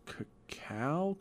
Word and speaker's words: cacao: You say cacao or cacao? – Cacao? Because that's the right way to say it.